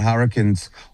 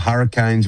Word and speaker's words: Hurricanes: Cricket Australia is determined to test the market with the Melbourne Renegades, Perth Scorchers and Hobart Hurricanes, or Hurricanes, whichever way you want to pronounce it, as proof the Big Bash privatisation push is far from dead.